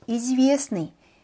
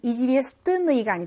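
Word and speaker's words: 이즈비에스니 (известный): 그다음 유명한 이즈비에스니. 자, SN 발음만 나죠. 때 발음이 안 나요. 그래서 이즈비에스니, 이즈비에스뜨니가 아니라 때가 묵음인 상태로 이즈비에스니라고 발음을 합니다.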